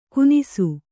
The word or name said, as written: ಕೂನಿಸು